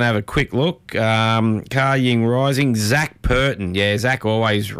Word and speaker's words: Ka Ying Rising: If you're wondering how it's pronounced, it's spelled Ka Ying Rising, so K A, then Y I N G, then Rising, so Ka Ying Rising.